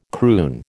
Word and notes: croon: American English

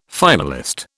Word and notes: finalist: British English

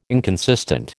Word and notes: inconsistent: American English

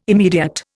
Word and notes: immediate: Indian

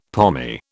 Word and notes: pommy: British English

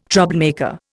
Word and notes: troublemaker: Indian

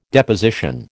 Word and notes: Deposition: American English

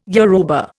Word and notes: Yoruba: Indian

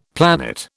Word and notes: planet: British English

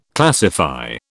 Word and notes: classify: British English

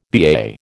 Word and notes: BAA: American English

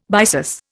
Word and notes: byssus: Indian

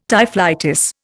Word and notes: typhlitis: Indian

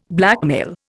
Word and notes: blackmail: Indian

